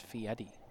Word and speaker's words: Fieri: Well, apparently, Garrett Robinson says we're pronouncing it wrong. It's Fieri.